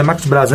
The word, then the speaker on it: Marcos Braz: A gente viu o que aconteceu ontem na chegada e o Marcos Braz, falei certo, né? Marcos Braz, né? Isso, isso.